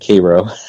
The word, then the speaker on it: Cairo: He's up in Cairo, in New York, or that, the way the locals pronounce it, Cairo.